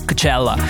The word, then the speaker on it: Coachella: Anzi, bisogna anche qua pronunciarlo come lo pronuncerebbero loro, cioè il Coachella.